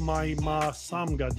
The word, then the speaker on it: Mahy-Ma-Somga: And on today, I have writer-director Theo Mahy-Ma-Somga.